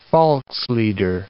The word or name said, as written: volkslieder